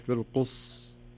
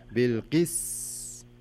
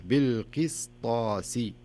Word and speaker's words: القسطاس: فتصبح بالقص قص والصحيح بالقس تنطق السين بحيث أنك يعني يسمونها إتمام الحركات أو إتمام الحروف بصفاتها ومخارجها بالقسطاسي ما أقول بالقص لا بالقسطاسي